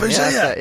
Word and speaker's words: Bugeja: Steve Bujega, B-U-G-E-J-A. – Yeah. Makes complete sense to say that as Bujega. – It does now, yeah. – it's like a soft G and a soft J. – Honestly, of all the times... – An invisible J.